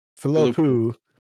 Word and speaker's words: Philippou: Thompson for Spider-Man Across the Spider-Verse, Danny and Michael Bilopi, I don't know how you say that. – There's no I. – Philippou for Talk to Me.